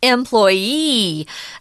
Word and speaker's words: employee: Notice the emphasis is employee. An employer, the emphasis is on the second syllable.